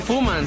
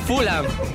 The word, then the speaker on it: Fulham: Y que no se dice Fuman, se dice Fulham.